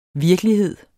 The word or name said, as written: virkelighed